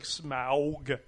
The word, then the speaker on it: Smaug: Smog. – It's like Smaug. Yeah. How they say it in the commercial.